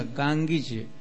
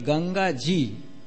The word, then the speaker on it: Ganga Ji: There's some funny pronounces from Ganga, Gangiji. Actually, word is Ganga Ji. So since British people couldn't say Ganga Ji, so they started Gangiji.